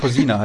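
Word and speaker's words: Kozina: Und da bin ich mir nicht sicher, wie es jetzt mit dem Krešimir Kočina, der so ausgesprochen, Cousina heißt er, ja, genau. Krešimir Cousina.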